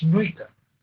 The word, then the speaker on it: Nuitka: Hablando de aceleradores, que hemos hablado muchos, otro que no se mencionó es Nuika. ¿Alguien lo conoce? – ¿Cómo se escribe? – N-U-I-T-K-A. Es con K, ¿vale? N-U-I-T-K-A.